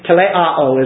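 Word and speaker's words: teleō: Teleao is really how you pronounce it.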